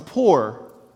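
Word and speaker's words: Pur: And they cast a pour. In the ESV, it's P-U-R, but the way we would pronounce it is P-O-O-R.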